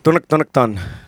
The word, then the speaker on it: Tonic Ton: The track we just heard, it's pronounced Tonic Ton? – Tonic Ton. – Excellent.